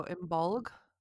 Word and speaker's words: Imbolc: Imbolc or Imbolic. – Okay. – Some people pronounce it with a G at the end, so Imbolc.